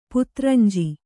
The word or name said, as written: ಪುತ್ರಂಜಿ